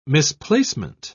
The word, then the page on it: misplacement: British English